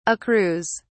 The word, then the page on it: accrues: British English